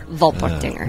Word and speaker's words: Volpertinger: Volpertinger. – Tinger, not Tinker. – Volpertinger. – And it's Tinger. – Volper, not Vorpal. – If you want to do a German pronunciation, it's Volpertinger. – Volpertinger.